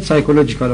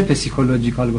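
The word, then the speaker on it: psychological: یه اصطلاح دیگر سایکولوژیکال اوردر، سایکولوژیکال اوردر یعنی ترتب روانشناختی یا نظم روانشناختی، لوجیکال اوردر، سایکولوژیکال اوردر، سایکو، سایکو تلفظ میشه، این پسیکو نوشته میشه، سایکولوژیکال در انگلیسی تلفظ میشه، در فرانسه پسیکولوژیکال گفته میشه، در فارسی هم ما میگیم بخوان ببینیم مثلا پسیکولوژی، ولی تلفظ انگلیسیش سایکولوژی هست، سایکولوژیکال اوردر.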